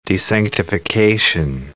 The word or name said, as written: desanctification